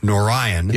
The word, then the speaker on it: Nouryon: Wawa and Narayan, I believe is how you pronounce this, or Narayan. N-O-U-R-Y-O-N. – I think you got it. – Narayan. – Yeah.